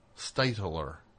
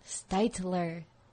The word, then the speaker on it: Stitler: Stitler. – Stitler. Two syllables.